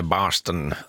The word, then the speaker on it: Boston: Stella Lefty sjunger om Boston, eller Barston som det tydligen uttalas på amerikanska engelska.